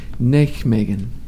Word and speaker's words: Nijmegen: Nijmegen. – Nijmegen.